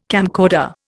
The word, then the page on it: camcorder: Indian